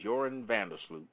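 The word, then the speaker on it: Joran van der Sloot: Now, Joran Vandersloot is his name, but it's spelled J-O-R-A-N, but it's Joran Vandersloot.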